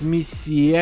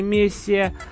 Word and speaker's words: мессия: Так, Слайк вот носом, ребят, решил ткнуть, правильно говорит, мессия, а не мессия.